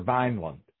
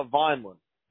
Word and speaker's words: Vineland: Vineland, New Jersey, is it Vineland or Vineland? – Vineland. – Vineland, okay. – I don't really pronounce the E in there, you know.